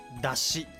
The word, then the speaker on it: 山車: 山に車と書いて出しと読む